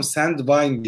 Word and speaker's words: Sandvine: Tabii ben böyle seni anlatırken cahilce Sandbin dedim ama Sandvine diye okunuyor galiba. Şu anda...